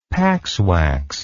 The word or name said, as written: paxwax